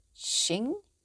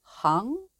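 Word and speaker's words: Xing (行): Now this character, Xing, it can also be pronounced as Hang, but since the theory is usually called Wu Xing, to keep things simple, I'll use Xing throughout.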